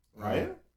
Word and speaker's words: Raelle: How would you say it? R-A-E-L-L-E. Rael? – Rael. – Rael. I'm going to go Rael.